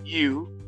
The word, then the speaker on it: Ryu: You, they pronounce U, R-Y-U, mm-hmm.